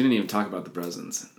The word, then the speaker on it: Brousins: B-R-O-U-S-I-N-S. Brezens.